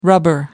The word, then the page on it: rubber: American English